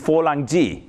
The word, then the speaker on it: Fulangji: Funny enough, in Chinese, the word for artillery is Fulangji, yes, which is the Chinese pronunciation for Frank, okay?